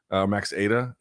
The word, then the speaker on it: Aita: Max Ida, it's pronounced, or his last name is spelled A-I-T-A.